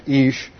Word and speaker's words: ish: And in the Old Testament, the Hebrew word for man is ish.